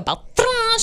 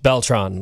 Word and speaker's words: Beltran: She's like, Bianca Beltran. She really rolls that R. And I'm go, girl. – Because there's a baseball player, Carlos Beltran. It was just pronounced Beltran. Like, that's all it was.